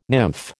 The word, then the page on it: nymph: American English